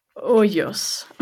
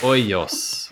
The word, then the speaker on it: Hoyos: Hoyas. Did I say that right? Benjamin de Hoyas. – Hoyos. – Hoyos.